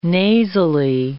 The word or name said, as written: nasally